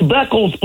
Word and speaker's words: Beckles: We're just mispronouncing names. It's Beckles, Playboy. BECKLES.